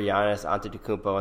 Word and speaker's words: Giannis Antetokounmpo: Some other good news is that the Boston Celtics went into Milwaukee and just shit all over Giannis Antetokounmpo and the Bucks.